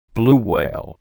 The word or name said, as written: blue whale